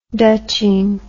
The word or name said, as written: dutching